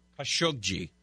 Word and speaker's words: Khashoggi: Khashoggi.